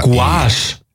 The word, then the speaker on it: Gouache: G-U-A-C-H-E. G-O-U-A-C-H-E. – Gouache. – Gouache. – Gouache? – So spricht man es aus.